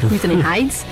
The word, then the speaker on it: Wuthering Heights: Isso aí, ela tá hoje aqui no Sessão das 6, porque o primeiro álbum que ela lançou uma música chamada Wuthering Heights. Nossa, como é que fala isso aqui? – Eu acho que é Wuthering Heights. – É, Wuthering Heights